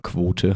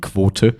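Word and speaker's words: Kvothe: Natürlich lese ich das dann immer wie Quote. Aber wahrscheinlich ist der Name eigentlich Quoth, was er auch irgendwie Buch mal erklärt.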